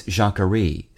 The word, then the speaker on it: Jacquerie: Today's word is Jacquerie, spelled J-A-C-Q-U-E-R-I-E.